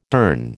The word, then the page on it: durn: American English